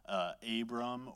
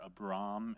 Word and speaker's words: Abram: I'm going to call him Abraham throughout this whole series, even though at this time, remember, his name is still Abram or Abram, if you want to pronounce it that way.